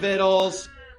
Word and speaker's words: victuals: We had plenty of victuals. Yeah, that word that looks like victuals, it's victuals, okay? Victuals is how you pronounce that.